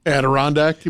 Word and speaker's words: Adirondack: And also raffle prizes, including electric golf cart, Henry rifle, and Andrew, chair and table set. – Adirondack, you mean? – Is that how you say it? – Yes. All right.